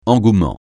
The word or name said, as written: engouement